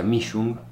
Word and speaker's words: Mischung: El apellido se pronuncia Mischung. – Mischung. – Significa mezcla. – Me parecía. – En alemán significa mezcla. – Ah, Mischung. – Pero igual yo siempre lo pronuncié mal, así que está todo bien. Yo siempre dije Mischung, porque si no nadie me entiende.